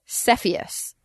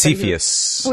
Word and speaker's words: Cepheus: named Cepheus. And am I pronouncing that right? – It's Cepheus. – Oh, it's Cepheus. Okay, cool.